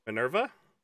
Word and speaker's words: Minerva: How do you pronounce this Op? – Minerva. – What? – Minerva? – Minerva. It's a Greek goddess.